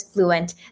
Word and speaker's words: Bluent: Our first uh, language that we're offering is actually business Chinese, and Bluent actually stands for business fluent. So, it's actually pronounced as uh, fluent but with a B.